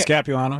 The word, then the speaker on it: Capuano: Capuano? – Chris Capuano, yep.